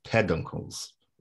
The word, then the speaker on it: peduncles: All right, sorry, I've been pronouncing that wrong. It's peduncles.